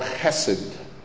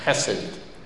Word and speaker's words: chesed: And the Hebrew word is called chesed. You have to pronounce it with a guttural sound, chesed. Okay? You write it in English, it's H-E-S-E-D, but you're supposed to say it in that way.